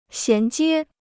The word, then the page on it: 衔接: xián jiē